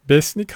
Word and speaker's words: Besnik: Besnik Hasi ya da Beşnik Hasi diye okunuyor artık.